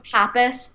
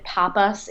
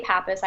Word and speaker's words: Papas: Yeah, Lauren Papas, but you know, actually, I think you pronounce it Papas in Greek if you're Greek, or if you're Spanish, you usually say Papas, but I say Papas, I Americanize it.